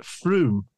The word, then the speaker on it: Frome: I think, if I'm correct, it may be pronounced Frome.